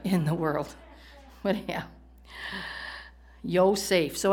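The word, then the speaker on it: Yo-Saf (יוסף): Now, the pronunciation in the Hebrew of Joseph, which I just thought was kind of funny, is Yo-Saf.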